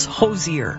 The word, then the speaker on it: Hozier: His name is like Cozier, except it's Hozier.